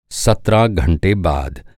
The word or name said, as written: सत्रह